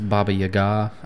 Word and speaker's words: Baba Yaga: It's Baba Yaga. I think it can be pronounced a few different ways, but that's how I've heard it the most.